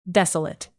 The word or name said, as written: desolate